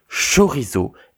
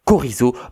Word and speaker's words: chorizo: Mais toujours est-il que c'est chorizo et pas chorizo parce que c'est pas logique.